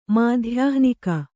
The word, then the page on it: ಮಾಧ್ಯಾಹ್ನಿಕ: mādhyahnika